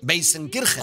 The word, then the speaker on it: Weißenkirchen: No tengo ni idea, es Weißenkirchen. – Es justo así. – Pero yo digo Weißenkirchen.